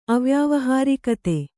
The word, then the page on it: ಅವ್ಯಾವಹಾರಿಕತೆ: Kannada